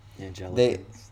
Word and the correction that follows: Anglican: I don't know, how do you pronounce it? Did I pronounce it wrong? – Anglican? – Anglican? – I think that's what it is.